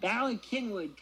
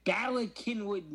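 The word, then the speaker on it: Bala Cynwyd: Ballykinwood, Pennsylvania, which is spelled C-Y-N-W-Y-D because it was like fucking Welsh Quakers founded this town in Pennsylvania.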